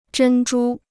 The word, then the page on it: 珍珠: zhēn zhū